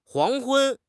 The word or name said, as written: 黄昏